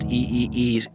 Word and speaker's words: Igor: And today, we will be talking about Tyler, Space, the Creator's new album, Igor, pronounced E-E-E-G-O-R.